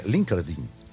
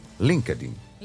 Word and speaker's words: LinkedIn: É LinkedIn. – LinkedIn.